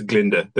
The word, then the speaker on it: Glinda: She says, it's Glinda, the girl is silent.